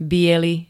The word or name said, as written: biely